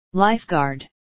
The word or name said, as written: lifeguard